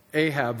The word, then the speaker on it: Ahab: It rhymes with Arab. Ahab, that's right.